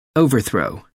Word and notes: overthrow: American English

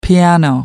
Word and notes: piano: American English

